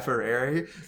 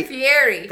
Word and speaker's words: Fieri: Oh, and the people that were handling it looked like Guy Ferrari. – How do you say it? – Fieri.